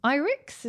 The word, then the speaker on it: Irix: Also, Irix, is that how you pronounce it? – Yep.